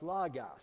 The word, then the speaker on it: logos: It's L-O-G-O-S, and it's pronounced logos. Some people pronounce it logos, okay? So long as the O's sound the same, I've heard either pronunciation for this very weighted word, a very important word to define and understand.